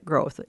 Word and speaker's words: Groth: Groth. It's spelled G-R-O-T-H.